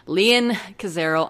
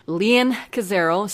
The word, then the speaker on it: Leon Cazero: And you can find me on Twitter at Leon Cazero. I know I pronounce it weird. I don't even know why. I think it was just because I was like 10 years old and thought it was cool.